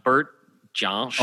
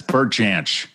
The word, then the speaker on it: Bert Jansch: I noticed Neil plays a little bit of a Bert Jant. I'm going to say it wrong. Bert Jansch. – Oh, Bert. Yeah, it's Bert Jansch.